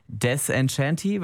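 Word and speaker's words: Désenchantée: You'll Be In My Heart von Phil Collins, Simple Life von Leonie und jetzt als erstes Kate Ryan mit Desenchanté, wird das ausgesprochen? – Desenchanté. – Desenchanté, ach, es ist französisch.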